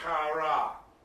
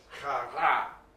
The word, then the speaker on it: kara: This is kara. Actually, if you can roll your R's, that's what you're supposed to do there. So it's kara.